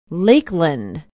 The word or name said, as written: lakeland